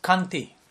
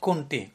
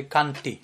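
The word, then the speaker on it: Kantimala: It's pronounced Kanti, Kuntimala. No, it's not Kunti. Kunti is the mother of the Pandavas. So sometimes I've heard many times about the saying Kunti, but it's actually Kanti,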